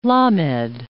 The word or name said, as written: lamed